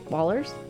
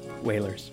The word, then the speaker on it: Wailers: Wait, Wallers? – Wailers. – I've always said Wailers, but for some reason I just looked at it and I was like, wait a minute, have I been saying it wrong all this time? – Wait.